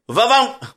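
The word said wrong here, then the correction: Vaffanculo: Well, man, I even put it phonetically and I messed it up. Va-fanculo. Which means F off.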